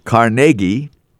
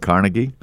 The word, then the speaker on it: Carnegie: Because it was on this date in 1963 that the Bell System introduced the first commercial touch-tone telephone system in Carnegie, and that's how they say it there, and Greensburg, Pennsylvania. I was corrected recently. I always used to call it Carnegie.